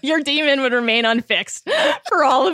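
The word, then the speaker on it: dæmon: It's spelled like Damon, but it's demon.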